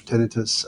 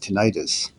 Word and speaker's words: tinnitus: And I should clarify, I noticed that you're pronouncing it tinnitus. Usually say tinnitus, and I've checked with the highest authorities in the field, among others, Dr. James Snow, who was formerly director of NIDCD and then ran one of the tinnitus foundations, the Tinnitus Research Consortium. He said absolutely both pronunciations are fine.